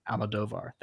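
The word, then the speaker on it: Almodóvar: Pedro Almodovar. – Almodovar, thank you.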